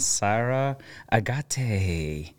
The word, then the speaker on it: Sara Agate: Today I have my friend Sara Agate from Munitask.